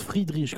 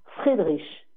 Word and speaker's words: Friedrich: pour avoir fait allemand LV2, ça se prononce Friedrich, comme s'il n'y avait pas de E. – Friedrich.